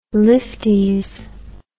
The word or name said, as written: lifties